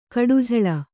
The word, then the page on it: ಕಡುಝಳ: kaḍujhaḷa